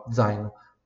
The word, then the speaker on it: zaino: Um zaino giallo, um zaino já a pronúncia dolce, né? Está aqui nos nossos exemplos, ó, zaino. Pronúncia dolce, som de DZ.